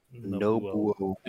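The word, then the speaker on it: Nobuo: Like, if you guys want to just, like, if people listening, obviously all of us are aware, but, like, is it Nobu? Is that how you say his name? – Nobu.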